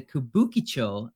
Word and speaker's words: Kabukicho: As a multi-floor extravaganza of bars, entertainment, in the Kabukicho red light district, and I did say that correctly because I researched it.